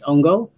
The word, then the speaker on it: hongo: It's H is not really pronounced, so it's Hongo, but yeah, it's spelled out exactly the same as Hongo, our last name.